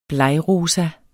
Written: blegrosa

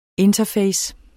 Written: interface

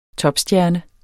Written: topstjerne

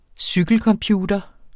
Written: cykelcomputer

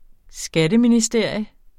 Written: skatteministerium

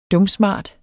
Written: dumsmart

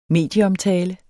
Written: medieomtale

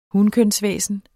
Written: hunkønsvæsen

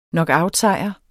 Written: knockoutsejr

